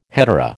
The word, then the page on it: hetaera: American English